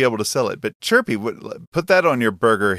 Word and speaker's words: chhurpi: From Nepal, it's Chirpy. That's spelled C-H-H-U-R-P-I.